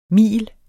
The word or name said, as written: mil